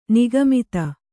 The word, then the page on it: ನಗೆಮಾತು: nage mātu